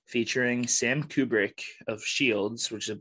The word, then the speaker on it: Kubrick: You are Stanley Kubrick.